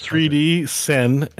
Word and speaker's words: 3D Sen: Yeah, it's 3D Sen, S-E-N. VR, all one word, 3D Sen VR.